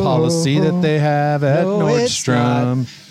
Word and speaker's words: Nordstrom: It's that most people mispronounce it and add an S to the end of the name. – Oh, is that right? Because there's no S at the end of Nordstrom.